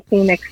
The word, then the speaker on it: Pheonix: Adam Curry finally said my name, and he said it right for a change, instead of screwing up the pronunciation of my non-NA name. He actually said Phoenix.